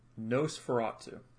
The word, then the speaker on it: NOS4A2: I think it was just something easy to have on the background, called Nosferatu. And it's spelled NOS4A2 because it's a license plate.